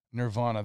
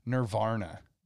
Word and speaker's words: Nirvana: I just have to add, one of the comedic aspects of Beavis and Butthead and Nirvana was they never pronounced it Nirvana. They always pronounced it as Nirvana. – They added an extra R, and I just died every time.